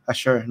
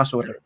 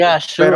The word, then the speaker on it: Azure: Microsoft ha hecho lo propio con Azure, lo dije bien, ¿verdad? Azure, no Azure. – Sí, Azure.